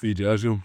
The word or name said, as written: VJ Edgecombe